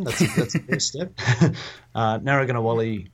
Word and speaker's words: Narragunnawali: Do you want to give us a big rundown of why, you know, sort of what Narragunnawali is, and I guess, you know, particularly why it's really important for services to engage with it.